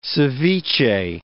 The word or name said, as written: seviche